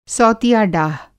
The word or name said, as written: सौतिया-डाह